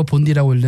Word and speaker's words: 본디: 이게 시티 샌메리 옆에 있는 하이드파크에서 출발해서 뭐 돌고 돌고 돌아서 본다이 비치까지 본다이라고 쓰고 본디라고 읽는 그 본다이 비치까지 14km를 달리는 대회입니다.